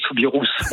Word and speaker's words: Soubirous: Est-ce qu'on dit Bernadette Soubirous ou Bernadette Soubirousse? – Alors, chez les Bigourdans, on va dire Bernadette Soubirousse. On va prononcer le S.